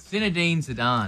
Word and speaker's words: Zinedine Zidane: And I knew that how do you pronounce his name? – It's Zinedine Zidane.